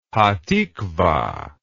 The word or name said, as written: Hatikvah